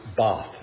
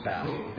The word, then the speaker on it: Bath: We went one day to Bath, England, and I was told it's pronounced Bath, Bath, not Bath.